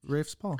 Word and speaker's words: Rafe Spall: Rafe Spall.